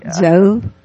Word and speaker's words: Zov: it's Zove, like in Jove, I guess. – Is it Zove? – Yeah. That's the official pronunciation, right, Zove? – Yes, it is Zove.